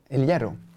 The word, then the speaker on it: El Hierro: Som hette, eh, nu får, uttalet här är oklart, men El Heiro. – El Hierro.